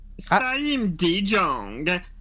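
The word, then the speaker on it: Siem de Jong: CM Dion, are we good on that? – I'm not sure. Let's ask our Dutchman. – Same De Jong. Same De Jong. – That's what I found on the internet. So I think that must be right. – That's close. – It must be close. – I'm looking forward to hearing the commentators on Match of the Day pronounce it. – Barry Davis or someone. – Same De Jong. Good, I like it.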